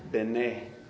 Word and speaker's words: Bene: Bene. It's pronounced like B-E-N-A-Y. Bene.